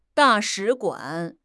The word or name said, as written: da4 shi3 guan3